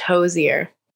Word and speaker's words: Hozier: It's pronounced like Cozier with an H, Hozier.